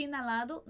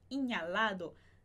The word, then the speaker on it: inhalado: E esse H de inalado não vai ser pronunciado, mesmo dando muita vontade. A gente que fala português vendo NH e querer falar inha, inhalado, não é assim que se fala, ok? Então, inalado.